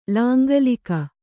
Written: ಲಾಂಗಲಿಕ

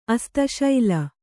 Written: ಅಸ್ತಶೈಲ

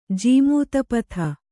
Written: ಜೀಮೂತಪಥ

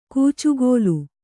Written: ಕೂಚುಗೋಲು

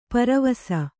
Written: ಪೆರ್ವಸ

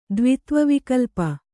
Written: ದ್ವಿತ್ವವಿಕಲ್ಪ

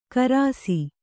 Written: ಖರ್ಸಿ

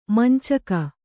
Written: ಮಂಚಕ

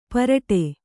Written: ಪಾರಟ

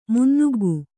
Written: ಮುನ್ನುಗ್ಗು

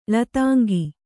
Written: ಳತಾಂಗಿ

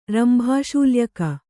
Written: ರಂಭಾಶೂಲ್ಯಕ

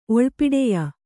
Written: ಒಳ್ಪಿಡೆಯ

